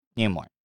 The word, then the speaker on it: Nimoy: All right, what's the right way to say his last name? Is it Nimoy or Nimoy? – Nimoy.